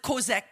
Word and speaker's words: chazak: You pronounce it kozak, but it's spelled C-H-A-Z-A-K.